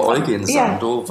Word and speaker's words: Eugen Sandow: Jürgen Sandow, Eugen Sandow? – Ich nenne ihn immer Eugen Sandow, weil es ein Preuße ist.